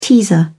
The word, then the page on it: teaser: British English